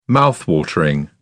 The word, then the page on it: mouthwatering: British English